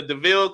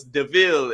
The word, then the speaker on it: DeVille: and then Horace rolled up in the DeVille car. He was like, this car is pronounced devil. And then Jasper was like, no, it's DeVille.